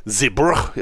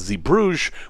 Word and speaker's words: Zeebrugge: and then all the way down to Zeebrugge, as I've been told it is pronounced, although maybe English speakers would think it was Zeebrugge, which is the port for Bruges, and then back to Southampton, and I'm not ready to talk about it yet.